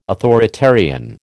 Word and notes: authoritarian: American English

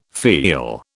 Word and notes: feel: British English

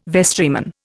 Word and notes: vestryman: Indian

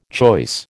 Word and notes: choice: American English

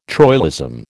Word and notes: troilism: American English

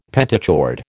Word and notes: pentachord: American English

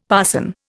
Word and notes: parson: Indian

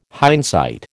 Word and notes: hindsight: American English

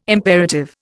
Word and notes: imperative: Indian

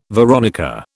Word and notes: veronica: British English

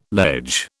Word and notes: ledge: British English

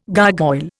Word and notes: gargoyle: Indian

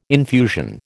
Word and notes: infusion: American English

